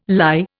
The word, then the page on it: lye: Indian